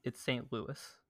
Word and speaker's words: St. Louis: It's St. Louis. That's how you pronounce that.